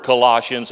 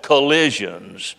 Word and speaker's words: Colossians: And he began to quote some scriptures from the book of Colossians, but he mispronounced the word Colossians. He called it collisions, the book of collisions.